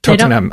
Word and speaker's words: Tottenham: Tottenham. It's like the Hebrew. You don't pronounce the H.